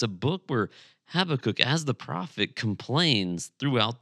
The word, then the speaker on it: Habakkuk: And by the way, when in doubt, pronounce it quickly, and it sounds like you know what you're doing, but it really is Habakkuk.